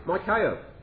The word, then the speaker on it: Michael: Or really, as it should be pronounced, Michael.